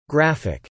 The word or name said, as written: graphic